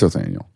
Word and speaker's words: Othniel: Is it Othniel or Othniel? – Just call him Othniel. – Oath. Oath? – It's Othniel.